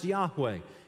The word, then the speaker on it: Yahweh: It was YHWH, and that's pronounced Yahweh. In the biblical Hebrew, there are no vowels, so there are no A-O-I-O and U's, right?